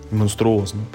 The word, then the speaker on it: монструозно: такую монстроузную игру. Монструозно, правильно говорить.